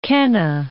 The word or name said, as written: Qena